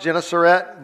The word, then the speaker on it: Gennesaret: It's pronounced Gennesaret, but that's also known as the Sea of Galilee.